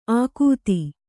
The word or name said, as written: ಆಕೂತಿ